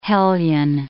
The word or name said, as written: hellion